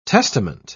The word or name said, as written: testament